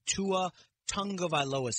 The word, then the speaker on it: Tua Tagovailoa: We're going to talk a little bit about the Tua Tagovailoa situation.